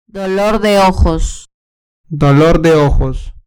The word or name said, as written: dolor de ojos